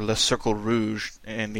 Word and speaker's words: Le Cercle Rouge: But here in the Red Circle, or Le Cercle Rouge in the anglicized pronunciation that I favor, we get a really, really cool, meticulous heist, and it's got this really neat French vibe to it.